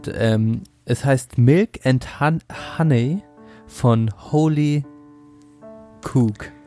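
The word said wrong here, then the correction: Holly Cook: Oder Holly, nicht Holy, Holly Ho Cook.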